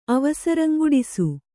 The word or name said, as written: ಅವಸರಂಗುಡಿಸು